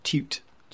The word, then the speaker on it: Tuite: How do I pronounce it properly? – Tuite. Yeah.